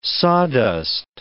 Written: sawdust

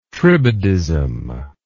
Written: tribadism

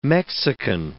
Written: Mexican

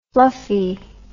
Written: fluffie

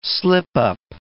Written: slipup

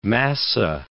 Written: massa

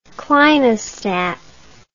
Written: clinostat

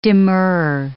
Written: demurrer

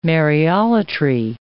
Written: Mariolatry

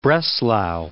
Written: Breslau

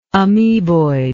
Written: ameboid

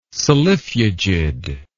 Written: solifugid

